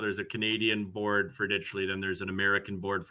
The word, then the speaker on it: Ditchley: and that is Ditchley. – Yeah. – Out of the, is it, I don't know what it is. I don't even know if that's how you pronounce it, but I guess that is how you pronounce it.